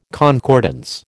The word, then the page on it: concordance: American English